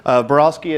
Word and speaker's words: Barolsky: What I have now is a presentation from Joel Barolsky, Barolsky Advisors.